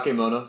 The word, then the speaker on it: Bakemono: Bakemono, I think it's called. – Bakemono. Ba- Bakemono sounds correct, yeah.